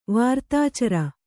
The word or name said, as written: ವಾರ್ತಾಚರ